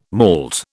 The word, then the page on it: malt: British English